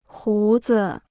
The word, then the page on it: 胡子: hú zi